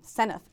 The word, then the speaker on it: Senft: And they'll be very proud that I finally learned how to pronounce his name, Seneff, S E N F T.